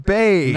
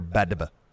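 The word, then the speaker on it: Badb: Well, I spent the first year of learning Celtic paganism, Celtic gods, pronouncing Bave Badba because I didn't know the DB phonetics. – Yeah, and I had no idea who you was talking about until I found out, oh, it's pronounced Bave.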